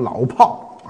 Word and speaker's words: 老炮儿: 那么老炮儿的炮呢？最初这个炮三点水这个炮呢是可以儿化的，而炮火连天的这个炮呢是不能儿化的。所以，当让我来念啊，这老炮呢应该念成老炮啊，老炮。